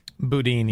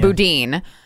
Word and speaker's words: Boudin: Boudin? – Boudin. – Yeah. Boudin.